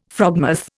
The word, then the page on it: frogmouth: Indian